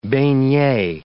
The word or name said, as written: beignet